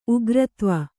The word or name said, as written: ಉಗ್ರತ್ವ